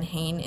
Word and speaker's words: Hayn: So Audrey is AUDREY and Hain is HAYN and then it's just Fit at the end.